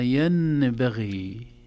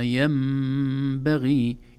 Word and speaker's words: ينبغي: وما ينبغي لهم، في الآية 211، وما ينبغي لهم، حرف الميم فوق النون، وهذا يدل على أن النون انتقل مخرجها إلى مخرج الميم، لا تنطق نون أبدا، لا نقول وما ينبغي، لا نقول هكذا أبدا، بل نقول وما ينبغي.